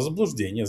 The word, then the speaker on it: заблуждение: Это заблуждение. Заблуждение это, как говорят в российской тоталитарной правовой системе. С ударением на второй слог.